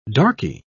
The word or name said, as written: darkie